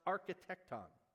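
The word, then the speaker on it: architecton (ἀρχιτέκτων): And the word builder in Greek, again, the language of your New Testament, the word that's translated builder is pronounced architecton.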